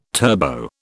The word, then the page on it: turbo: British English